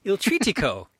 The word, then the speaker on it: Il Trittico: Il Trittico? – Trittico. – Il Trittico. Puccini's three-part journey is now at the Metropolitan Opera. And Michael saw it. So, Michael, tell us about Il Trittico.